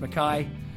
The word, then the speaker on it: Mackay: Mackay is spelled McKay, pronounced Mackay, and I'm not very good at doing that distinction.